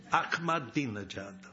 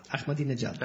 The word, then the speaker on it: Ahmadinejad: It's an unpronounceable name. Some people call him Ahmad's dinner jacket. – Det noen som kaller ham for Ahmeds dressjakke, men det høres omtrent likt ut på engelsk. – Ahmadinejad.